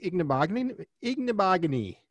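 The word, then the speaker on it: Igbinoghene: I like Ignabogany. – Oh, right? And you said the name right.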